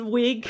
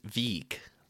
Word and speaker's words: Wiig: Am I saying her name correctly? Kristen Wiig, yes. Yeah, as Brenda, the hot dog bun. – I like the way you were going to say her name as Wieg there.